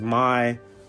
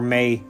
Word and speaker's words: Mai: And with that being said, pretty cool character that I really didn't remember much was Mai. Or Mei, as it's pronounced.